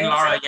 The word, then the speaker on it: Ian Lara: Ian Lara, is that how you say it? Ian Lara, yeah, really funny comedian from New York, Dominican dude, Dominican-American dude, really just a, I mean, super funny guy.